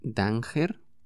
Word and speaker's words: danger: Se escribe danger pero se pronuncia danger, danger.